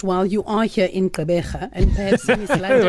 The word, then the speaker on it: Gqeberha: Gqeberha.